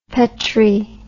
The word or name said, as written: petri